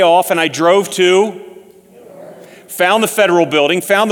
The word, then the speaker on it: Newark: Do you pronounce that city's name Newark? Everybody raise your hand if you pronounce it Newark. How many of you pronounce it Newark? Okay, you're wrong. It's Newark.